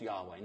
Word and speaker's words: Yahweh: In Hebrew, the name is probably pronounced Yahweh. No one's 100% sure. Yahweh is not like Bill or Bob.